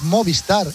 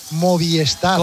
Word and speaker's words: Movistar: Y Sepp Kastner, y hay mucha gente que pronuncia mal Movistar. Es Movistar. Y la gente dice Movistar.